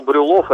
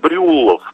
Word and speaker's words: Брюллов: М3 пишите как Брюлов. Почему-то вот именно с таким ударением он это произносил.